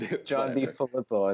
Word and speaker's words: DeFilippo: But so they just fired John DeFilippo. Do you think that that could their offensive coordinator? Did I pronounce that right? – John DeFilippo. I think it is.